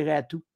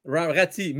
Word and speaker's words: Raty: Aujourd'hui, Aujourd'hui, toutes les analystes que j'ai écoutés, ils ont dit la, la pièce maîtresse, aujourd'hui, c'est Ratou. – Rati, mais. Ben, ah! c'est Ratou, ça a que ça se prononce. – OK, c'est compliqué cette histoire. le Y, ça ça se prononce U.